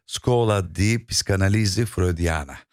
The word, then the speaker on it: Scuola di Psicanalisi Freudiana: Daha doğrusu orada da tam olarak belki telaffuz edemiyorum ama Scuola di Psicanalisi Freudiana. – Harika, doğrudur.